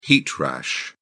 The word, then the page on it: heat rash: British English